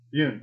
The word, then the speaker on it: Yeun: Steven Wynn? Wynn? – Is it Wynn? I thought it was Wynn. – Y-E-U-N. How do you say it? – I thought it was Yoon. – Yoon. Okay, Yoon.